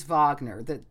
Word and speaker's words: Wagner: That was, well, actually, probably when I was a kid, I thought it was Wagner when I read it, but it is Wagner. That's just how you pronounce the W in German.